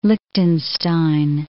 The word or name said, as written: Lichtenstein